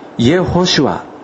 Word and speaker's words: イエホシュア: 日本語の聖書ではヨシュア、ヨシュア記となっていますが、ヘブライ語の発音により近づけるとイエホシュアとなるそうです。